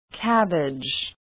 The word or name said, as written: cabbage